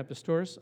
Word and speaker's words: Pistorius: You guys know this guy, Oscar Pistorius? I think that's how you say his name. Yeah, Pistorius.